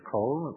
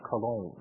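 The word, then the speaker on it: Colón: Though I many of my Jewish students says Cologne? I said, no, it's Cologne. It's with a little accent on the second O, Cologne.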